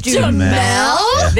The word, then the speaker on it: Duhamel: Josh Duhamel? – There we go. Didn't know how to pronounce his last name. Yes. So this man looks like Josh Duhamel.